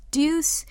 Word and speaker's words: Deux: Korean pronunciation is deuce. English spelling is D-E-U-X.